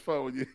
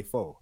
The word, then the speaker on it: pho: It's pho. – I want to just call that shit pho because it's spelled P-H-O. I just want to use my American brain and say faux.